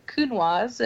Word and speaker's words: Counoise: It's called Counoise. It rhymes with coon paws, like a raccoon.